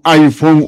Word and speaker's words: iPhone: Usted pues que anda con su teléfono iPhone última generación. – Ay, o sea, qué bien que lo haya pronunciado correctamente, porque don Burdo siempre se equivoca.